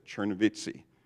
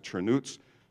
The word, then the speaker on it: Chernivtsi: Our American tongues would pronounce it Chernivtsi, if you look at it on a map. But they pronounce it Chernuts.